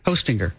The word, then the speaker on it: Hostinger: H-O, like Santa, ho, and then stinger. – Hostinger. – Like a bee has a stinger.